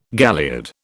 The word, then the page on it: galliard: British English